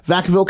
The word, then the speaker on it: Vacaville: We were doing a bunch of shows going down the West Coast, and we stopped in, I think, Vacaville, if I'm saying that right. – Excuse me? – Vacaville, California, yeah.